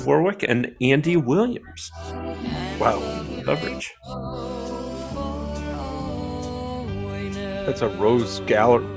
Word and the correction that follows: Rogues Gallery: Rogues Gallery?